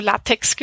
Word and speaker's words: LaTeX: Also LaTeX geschrieben, aber LaTeX ausgesprochen.